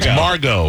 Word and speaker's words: Margot: Margot. – It's Margot Robbie. – M-A-R-G-O-T. Margot. – That's Margot. – There's a T. – Yeah. – I like to pronounce it. – D-U-M-B.